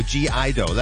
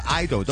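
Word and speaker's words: (G)I-DLE: 电台 Hong Kong Top Ten 本周推介，虽然呢一对韩国嘅全女班咧，我哋平时嗌做 G Idol 咧，但系其实咧有另外一个叫法咧，可以就咁样叫 Idol 都 OK 嘅，因为括弧嗰个 G 咧可以唔读音嘅。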